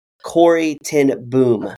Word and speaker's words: Corrie ten Boom: I want to tell you a quick story about Corrie ten Boom. That's how you pronounce it.